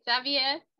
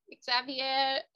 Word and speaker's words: Xavier: And I just realized I've been pronouncing your last name incorrectly. How do you pronounce that in Portuguese? – Xavier. – Oh, Xavier. Xavier. Okay, I'll work on that. – No, but I understand in English it's pronounced that way.